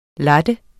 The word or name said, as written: latte